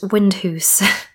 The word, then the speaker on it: Windhouse: Some internet sources say it's pronounced Windhoose, but spelt Windhouse, W-I-N-D-H-O-U-S-E.